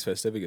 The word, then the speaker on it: Festivus: So now we got a summer special, and we have Thanks Festivus. It's like two points in our year that we can look forward to every time. That's right. Before you know it, Thanks Festivus is going to be here, which I'm so excited for.